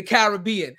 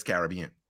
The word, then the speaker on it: Caribbean: Is it Caribbean or is it the Caribbean? How do we pronounce it? – The pronunciation Caribbean is an American construct. Everybody else in the world says Caribbean.